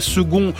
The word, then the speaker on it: second: Pourquoi second se prononce-t-il comme s'il y avait un G ?